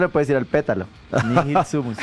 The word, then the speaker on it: Nihil sumus: ¿Cómo se pronuncia este Nihil sumus. – Nihil sumus. – ¿Cómo? – Nihil sumus.